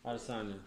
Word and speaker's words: Adesanya: He wants to go Israel Alessandre on her ass right now. – What? Alessandre? – What's, how do you say that? Adesanya? – Adesanya, sorry.